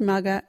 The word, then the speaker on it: maga: You can use mugger in this sentence. Put that hat on your mugger. Mugger is spelt M-A-G-A. Can you say mugger?